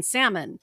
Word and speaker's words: salmon: pronounce the L in salmon.